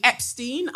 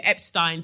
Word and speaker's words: Epstein: So my straw of the week goes to Dr. Jeffrey Epstein. I would call it Epstein, but I know that Americans don't like to pronounce E-I, but Epstein.